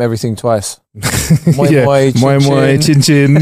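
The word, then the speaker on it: moin moin: And it's moi moi with an N, but you don't really pronounce the N because a lot of Nigerian have silent letters like G's and stuff.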